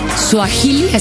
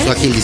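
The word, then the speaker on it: suajili: lengua y cultura Suahili, así se pronuncia. – Suahili. sí.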